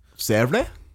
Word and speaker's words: Sèvres: Sèvres. Hmm. I think I get that pronunciation right. – Yeah. No, I just never heard those sounds before. Sort of rolled an R in there or something.